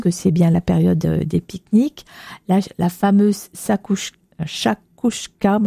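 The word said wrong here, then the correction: chakchouka: je ne sais jamais le dire, c'est très bon, mais difficile à prononcer. – Chachouka, non? – Oui, oui. – C'est chachouka? – Oui, moi j'ai... – Non, c'est ça, chachouka.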